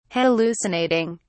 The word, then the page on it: hallucinating: British English